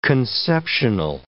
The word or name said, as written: conceptional